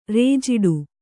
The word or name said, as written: ರೇಜಿಡು